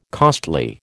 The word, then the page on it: costly: American English